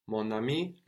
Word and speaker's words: mon ami: We also notice how the N joins on to the next word. In sound, not in writing. Mon ami. It joins on to the next syllable rather than just really pronouncing the N more on mon. No? Mon ami. No, the N joins on to the next word. Mon ami.